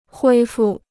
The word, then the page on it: 恢复: huī fù